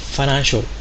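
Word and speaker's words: financial: ファナンシャルフィナンシャルという発音でもいいですね。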